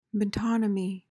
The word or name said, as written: metonymy